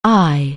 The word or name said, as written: aye